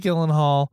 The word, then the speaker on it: Gyllenhaal: Jake Gyllenhaal.